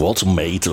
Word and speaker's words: Woltemade: Nick Woltemade, übrigens will man immer das gleich Englisch aussprechen, Woltemade oder so, aber ja, er wird wirklich Woltemade ausgesprochen.